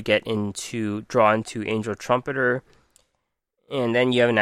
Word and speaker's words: Trumpeter: So the core of the deck, you got three Angel Trumpeteers. Trumpeter, I've been saying it wrong the whole time.